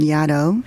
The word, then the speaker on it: Yaddo: this residency called Yaddo, which is supposed to rhyme with shadow. I, I, that's how the name the, the somebody made up that name and they, they thought it was funny that it rhymed with shadow.